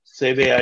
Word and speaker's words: cébé: All right, so lastly, you are the owner and principal consultant of a company called CB. I hope I'm pronouncing it correctly. CB IT and Knowledge Management? – Yeah, it's cébé IT and Knowledge Management, yes.